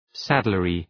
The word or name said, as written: saddlery